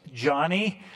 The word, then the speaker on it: Joni: Yes, it's pronounced Johnny.